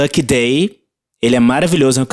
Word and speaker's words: Lucky Daye: é, Lucky Day, Lucky Day, se escreve L-U-C-K-Y, da, daí, né, D-A-Y-E. C-K-Y.